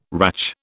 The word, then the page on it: ratch: British English